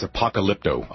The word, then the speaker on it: Apocalypto: It's Apocalypto. – Oh, Apocalyptolo. – Yeah. No, not that one.